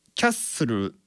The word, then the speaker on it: Castle: そしてまああとはですね、サビとかですね、 Building Castles in the Sky、これも Castles in のこのずっていうのとインっていうのがつながって Castles in ってなってるんですよね。さらにその L の発音、 Castle の L の発音っていうのはこれ日本語のルのような発音ではないんですよ。ウとかオのような発音に近い、まあ、あるいは全く聞こえてないかなっていうふうに発音になっているんですよね。